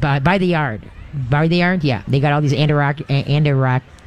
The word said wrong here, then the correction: Adirondack: Adirondack? – Adirondack.